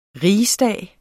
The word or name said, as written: RIGSDAGS